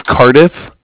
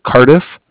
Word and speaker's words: Cardiff: Yeah, this is Bank of Cardiff. You spell that C-A-R like car and then D-I-F-F like Frank there at the end. So Cardiff.